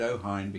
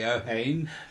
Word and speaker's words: Ohain: They pronounce it Ohain because that's the way the Germans pronounce it, but it's written H-A-I-N. So you and I say Ohain, but we're wrong.